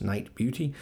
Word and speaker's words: Nite Bjuti: It's spelled N-I-T-E-B-J-U-T-I, but pronounced Night Beauty.